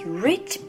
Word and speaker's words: rich: She has rich black hair. She has rich black hair. Rich, rich. R 発音ですね。口を尖らしてみてください。